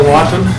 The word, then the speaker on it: Lawton: My name is Bill Lawton. It's L-A-W-T-O-N.